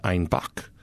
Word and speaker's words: Einbeck: So they started talking to their own brewers and said they wanted Einbock, which is how they pronounced the word Einbeck.